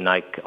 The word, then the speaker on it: Nike: You, during the period, you write that you pulled back some of your Nike exposure, or Nike as one pronounces it, added Adidas to it.